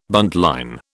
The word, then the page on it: buntline: British English